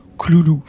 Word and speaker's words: Cthulhu: Hein, Joshi, lui, le prononce Cloulou.